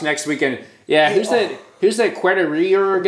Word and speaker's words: Quartararo: Quartararo. You know why you can't say it right? – Quartararo. – He's French.